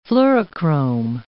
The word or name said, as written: fluorochrome